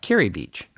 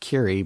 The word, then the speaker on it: Kure Beach: We all would share this beach cottage down at the beach in Kure Beach, North Carolina, pronounced Curie, but like Madame Curie, but spelled K-U-R-E.